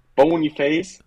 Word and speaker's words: Boniface: Der, oder Boniface, ich muss mich entschuldigen an die Leverkusen-Fans, ähm, wie er ausgesprochen wird, aber mit. Bo Boniface. Boniface, genau, ähm, jetzt äh, gewechselt zu Leverkusen, ist äh, für mich mit 22 Millionen, glaube ich, ähm, als Stürmer ein Schnapper.